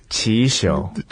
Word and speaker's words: Qixiu: Chinese name is Chi Show. – Chi Show. – Like Q-I-X-I-U, like some the way Chinese people spell it. But it'll be like the Chi Show.